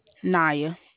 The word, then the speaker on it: Niya: Naya. – N-I-A-A. How do you spell that? – N-I-Y-A. – N-I-A-Y? – No, N-I-Y-A.